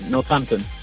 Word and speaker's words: Northampton: Oye, ¿lo he pronunciado bien? ¿Northampton o se dice de otra manera? – Sí, sí, Northampton.